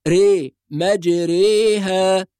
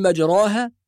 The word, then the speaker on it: مجريها: نعم، لو نظرنا إلى المصحف هنجد دائرة مطموسة تحت حرف الراء، وهذه الدائرة معناها أن حرف الراء ينطق بالإمالة، فلا نقول مجراها ولا نقول مجريها، لا تنطق فتحة ولا تنطق كسرة، وإنما تنطق بين الفتحة والكسرة، هذا هو معنى الإمالة.